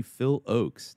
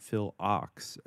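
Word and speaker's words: Phil Ochs: It is apparently Phil Oaks, not Phil Ox, as I always used to say.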